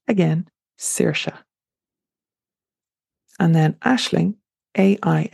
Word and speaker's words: Aisling: Again, it's pronounced Aisling.